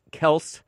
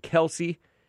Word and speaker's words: Kelce: Anyway, Taylor Swift is going to get married to Travis Kelce. Some people pronounce it Kelsey because these Kelces are too weak to correct anybody.